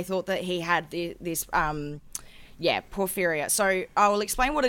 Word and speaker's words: porphyria: Porphyria. It's P-O-R-P-H-Y-R-I-A. He was previously thought to have suffered this condition, porphyria.